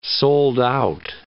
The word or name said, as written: sold-out